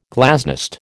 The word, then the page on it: glasnost: American English